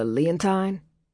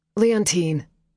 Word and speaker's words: Leontine: He pronounced it to rhyme with Valentine, as if he hadn't paid any attention to the way the woman on the phone must have pronounced it. – Leontine, Leo automatically corrected.